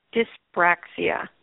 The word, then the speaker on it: dyspraxia: but she's saying it's dyspraxia, which is because of the jaw thing.